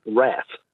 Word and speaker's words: wrath: In recent times, the word wrath, I've heard pronounced wrath. – Ah. – I'm just wondering how that's crept in. – Yes. W-R-A-T-H, meaning anger. Wrath is American. And for some reason, they've decided to pronounce things differently, just as in American English, solder is sodder for some reason.